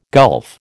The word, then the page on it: gulf: American English